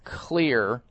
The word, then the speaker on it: Xlear: The nasal spray is called Clear. It's spelled X-L-E-A-R.